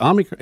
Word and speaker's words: Omicron: How do we say that? Omicron. It's O-M-I-C-R-O-N.